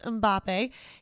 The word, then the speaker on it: Mbappe: For France, you should know Kylian Mbappe. It is pronounced Mbappe.